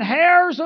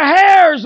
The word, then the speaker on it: heirs: He said, I want to talk about being heirs of God. I want to put a sign that said silent H. He said, we are heirs of God, church. We are joint heirs with Christ.